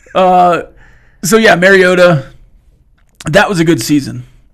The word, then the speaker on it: Mariota: So it's Mariota.